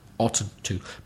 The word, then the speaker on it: oughtn't: If you're wondering about the spelling, it's, as you would expect, OUGHTN'T, and it's pronounced oughtn't, followed by to, oughtn't to.